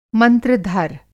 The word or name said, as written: मंत्र-धर